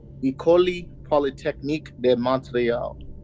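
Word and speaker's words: École Polytechnique de Montréal: So he had moved out of his mom's home, got his own apartment, and in 1986 he applied to study engineering at I'm gonna butcher this school, Ecole Polytechnique de Montreal. – Oh, you did pretty good.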